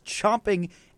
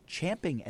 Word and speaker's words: champing: Turns out that phrase is actually properly pronounced champing at the bit. It's a verb that is in reference to horses.